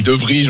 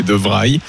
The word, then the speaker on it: De Vrij: Moi, je dis De Vrij. On me dit n'importe quoi, c'est De Vrij.